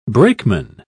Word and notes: brakeman: British English

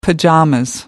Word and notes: pyjamas: American English

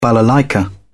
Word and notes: balalaika: British English

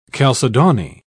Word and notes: calcedony: British English

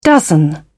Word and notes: dozen: British English

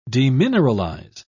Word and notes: demineralize: British English

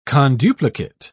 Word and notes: conduplicate: British English

